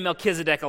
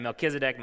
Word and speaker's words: Melchizedek: I'm going to say Melchizedek a lot, so I'm going to try and pronounce that correctly every time. Melchizedek, Melchizedek, Melchizedek.